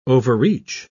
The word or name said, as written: overreach